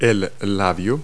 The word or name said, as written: lip